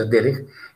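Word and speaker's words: Derech (דרך): que se escribe Derech, se pronuncia Derech, empieza con la letra Dalet.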